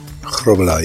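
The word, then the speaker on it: Grobbelaar: And let's get the pronunciation right. I believe it's Grobelaar. – Grobelaar, yeah.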